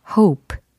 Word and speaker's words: hop: 발음 차이가 분명히 있죠. 예, 바라다, 희망은 hope. 요거고, 그 다음에 깡충 뛰어오르다 라고 할 때는 hop. hop. 예, 이렇게 좀 더 짧아지죠. 예, hop, hop. 모음이 좀 달라지죠.